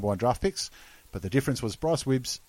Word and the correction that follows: Gibbs: Jeez. Bryce Gibbs. – Correct.